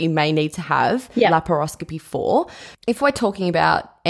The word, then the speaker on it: laparoscopy: Okay, so I think now I've got the pronunciation of laparoscopy.